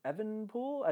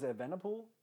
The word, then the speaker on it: Evenepoel: I always thought his name was Evenepoel. I didn't know it was Evenepoel. – Yeah, they say it wrong pretty often.